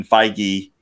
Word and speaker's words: Feige: Kevin Feige uh, I did pronounce his last name correct, right? – Yes, you did.